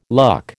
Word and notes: luck: American English

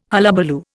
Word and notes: hullabaloo: Indian

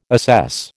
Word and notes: assess: American English

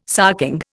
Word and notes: sarking: Indian

